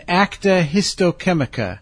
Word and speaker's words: Acta Histochemica: and Acta Histochemica. I may have mispronounced that one. We'll find out later. – Oh, you did fine with that.